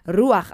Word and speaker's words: ruach: Anyway, R-U-A-C-H. R-U-A-C-H. Ruach. It sounds like some sort of food. Ruach. Like you can walk into a restaurant and ask for ruach, maybe.